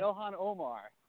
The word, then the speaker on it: Ilhan Omar: Congresswoman Iman, am I saying her name right? – Omar. – And you said Ilhan Omar. – Yes, Congresswoman Omar.